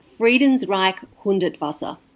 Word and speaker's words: Friedensreich Hundertwasser: where visionary architect Friedenreich Hundertwasser, how's that? Friedenreich Hundertwasser, how do you say it? – It's Friedensreich Hundertwasser.